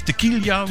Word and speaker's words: tequila: Maar die zei tequila of tequila, hoe moet je dat eigenlijk uitspreken? Want jij weet het daar, hè? – Ja, het is tequila.